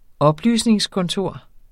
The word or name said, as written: oplysningskontor